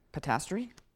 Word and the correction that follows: posterity: I don't know how to pronounce that. Posterity. Thank you, Ben.